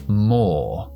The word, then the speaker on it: more: que es more. More. La E final no se pronuncia nunca, así que OR, O. More.